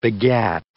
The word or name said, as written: begat